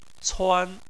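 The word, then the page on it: cuan: tone 1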